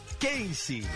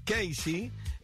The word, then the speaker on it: Casey: Si uno pronuncia el apellido en inglés, Casey, es lo mismo que poner dos letras, la K y la C, que en inglés se pronuncian, la K se pronuncia como K y la C como C.